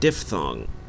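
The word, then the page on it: diphthong: Canadian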